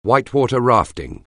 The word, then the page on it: whitewater rafting: anglais